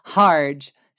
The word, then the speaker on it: Harge: Harge, spelled like large but with an H.